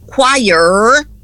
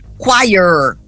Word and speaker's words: choir: Então, em vez de quiet, você vai dizer choir e puxar bem o R, bem goiano. Choir, choir. Então, pronto. Se você lembrar de the choir is not quiet, você não esquece nunca mais a pronúncia.